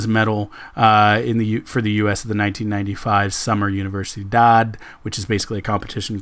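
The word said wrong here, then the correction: Universiade: Oh, it's Universiad is how you pronounce it. I'm sorry, because it's a combination of Olympiad and University.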